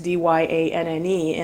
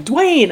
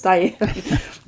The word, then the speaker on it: Dyanne: They're like, Dwayne. I'm like, no, I'm a girl. I promise. It's Dyanne. They look at the Y and they see Dwayne.